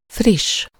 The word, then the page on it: quick: American English; British English; /kwɪk/